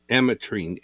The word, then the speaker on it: Ametrine: Today's stone is Ametrine. – Ametrine, A-M-E-T-R-I-N-E.